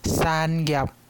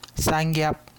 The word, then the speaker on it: 产业: 第二段，好光景无久长，产业豆豆啊刷去国外，周小秋甲红婿林金顺嘛去中国投资，几若当前转来。发觉讲手工雨伞业真稀微，因怎啊甲中国的生意收煞，要恢复 MIT 的好名声。这段特别注意第二句的产业，读作产业，产业。